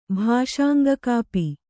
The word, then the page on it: ಭಾಷಾಂಗಕಾಪಿ: Kannada